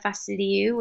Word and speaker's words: Vasiliou: So uh, my Instagram is Andrea Vasiliou,